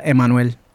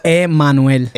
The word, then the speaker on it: Emmanuel: It's Emmanuel. Emmanuel. – Emmanuel. – See, Manuel. Manuel. See, that is so in school when the teacher started saying it like that, I just call me Manny.